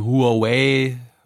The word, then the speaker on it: Huawei: auch um Huawei, und so weiter und so Seitdem fort. Seitdem wissen wir, wie es ausgesprochen wird. Drei Silben Huawei.